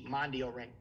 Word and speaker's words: Mondioring: It's spelled MONDIORING, Mondial Ring, which means world ring.